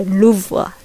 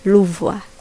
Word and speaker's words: Louvre: 各位不好意思哦，不叫福禄宫哦，是叫做卢浮宫，英文叫做 Lu Louvre，应该是法国法语吧。然后呢，我不知道怎么念，我上网看的 pronunciation 是 Louvre Louvre，所以就是 Louvre Museum，OK？